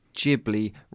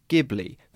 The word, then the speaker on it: Ghibli: And for any fans of hard G sounds out there, to give you a warning, we will be committing to a soft Ghibli rather than Ghibli for this series.